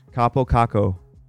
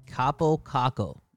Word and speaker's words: Kaapo Kakko: Kaapo Kakko. – Kaapo Kakko.